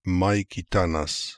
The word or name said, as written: Maikitanas